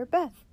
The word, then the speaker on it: Beth: "We pronounce this letter Beth," he said.